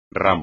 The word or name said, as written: ramo